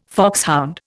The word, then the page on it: foxhound: Indian